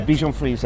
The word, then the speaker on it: Bichon Frise: Yeah, Bichon Frise.